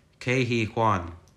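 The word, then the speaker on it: Ke Huy Quan: Kei-Hee Kwan.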